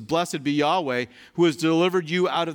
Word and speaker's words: Yahweh: And again, when you see all capital letters for Lord, it is a reference to the covenant name of God, which is, we think the best way to pronounce it is Yahweh.